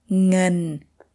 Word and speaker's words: Ngân: Like, for example, the first sound is the NG sound. Can you pronounce the word money? – Ngân. – Ngân.